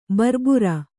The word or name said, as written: ಬರ್ಬುರ